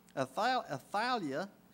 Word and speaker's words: Athaliah: And Jehoram married Ahab's daughter, Athaliah is how you're supposed to pronounce it.